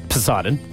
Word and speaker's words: Poseidon: Poseidon.